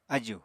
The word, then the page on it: aju: apatani